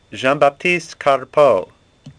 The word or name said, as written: Carpeaux